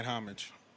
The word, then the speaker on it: homage: Or are you saying homage? – I say homage, homage. I always, sometimes I pronounce French? – The H. Yeah, sometimes I pronounce the H, sometimes I don't.